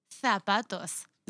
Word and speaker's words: zapatos: Because Sandra is from Spain. She says the Z's with THs. Thus. – TH? – For them. – TH. Oh, okay. For them. – Th, like zapatos. Okay, so how do you say... – The difference is that she puts the tongue right below the teeth.